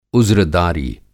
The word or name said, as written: उज़्रदारी